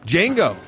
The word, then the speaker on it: Django: Django. It's the D is silent there.